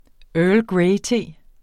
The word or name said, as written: earl